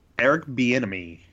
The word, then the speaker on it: Bieniemy: Eric Bieniemy.